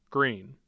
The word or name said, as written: greene